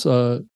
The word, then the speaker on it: Strava: It's called Strava. You heard of this before? – Strava? – It's Strava. Strava.